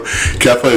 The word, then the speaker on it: Kepha: Kefa is K-E-P-H-A. It's Aramaic for Peter.